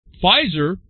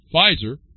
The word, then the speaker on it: Pfizer: Pfizer, silent P, Pfizer, begins trials to bring COVID inhibitor in pill form.